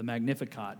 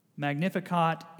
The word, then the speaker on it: Magnificat: I think that's how you pronounce it, the Magnificat.